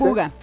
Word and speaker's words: Buga: Eso del Buda o Bura o cómo se dice. – Buga. B-U-G-A.